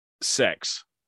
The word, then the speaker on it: CeX: It's spelt the name of the place C E X. Yeah, it's commercials on the telly. Call it sex.